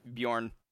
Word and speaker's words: Bjorn: B-J-O-R-N? – Bjorn.